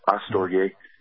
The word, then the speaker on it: astorge: And Paul used the word storge, astorge, which in the Greek, the letter alpha, which we pronounce, you know, astorge, before the word storge, which is family love.